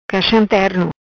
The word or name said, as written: Caxam Terno